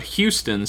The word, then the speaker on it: Hughestown: I lived in a town called Houston, spelled like Hughestown.